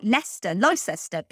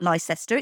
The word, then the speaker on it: Leicester: I suppose it's the same as when American people pronounce towns like Leicester, because it looks like Leicester, but it's not Leicester, it's Leicester, and exactly the same thing.